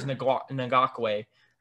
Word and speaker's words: Ngakoue: Defensively, they signed Yakin Ngakoue. I think that's how you say his name. I know how to say his last name is Ngakoue.